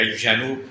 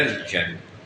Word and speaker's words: الجنوب: حنين الجذع أرجوكم، من أحد الأخطاء الشائعة هو حرف الجيم، حرف الجيم حرف قمري، القمر يعني يجب تلفظ الألف واللام، تمام؟ مثلا في القرآن نماذج كثيرة، مثلا تقول أنا ذاهب إلى الجنوب، غلط، إلى الجنوب، مثلا الجنة، إذا قرأت في القرآن الجنة ما بيمشي الحال، الجنة هذا خطأ شائع جدا، وهذا إذا وقع في سورة في الصلاة يبطل الصلاة.